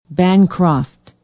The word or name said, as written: bancroft